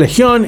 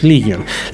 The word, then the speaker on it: Legion: O Legion, como lo encuentran ustedes, depende de quién lo pronuncie. En español Legion, en inglés Legion.